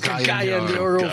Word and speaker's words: Cagayan de Oro: No, it's Cagayan de Oro, Philippines.